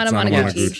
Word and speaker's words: Anamanaguchi: It was uh I'm gonna butcher this. On Anamanaguchi. – That's correct. – Anamanaguchi.